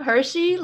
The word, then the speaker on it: Hershey: Yep, Hershey, like the chocolate and the pronouns.